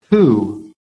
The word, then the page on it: WHO: American English